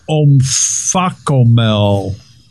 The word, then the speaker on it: omphacomel: It's called a omphacomel. O-M-P-H-A-C-O-M-E-L. – Omphacomel. I think it's omphacomel.